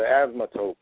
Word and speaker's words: asymptote: It's pronounced nothing like it's written, okay? – Right, exactly. – Because if you look at it, it says asymptote, right?